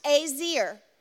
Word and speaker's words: ezer: It's pronounced easy, or it's spelled E-Z-E-R, but it's pronounced azir.